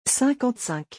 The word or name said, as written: 55